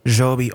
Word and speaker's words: Zhou B: That's spelled Z-H-O-U-B Art Center. Say it for me. How do you say it? – Joe B.